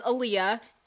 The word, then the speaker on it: Aaliyah: Anyway, my full name is Aaliyah, and that is spelled with two A's at the beginning, L-I-Y-A-H.